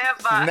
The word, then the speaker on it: Neva: Neva. Neva, sorry, Neva.